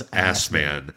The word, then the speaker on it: Assman: Are we sure it's pronounced that way? – Yeah. Yeah, it's Hans Assman. A-S-S-M-A-N-N. Assman.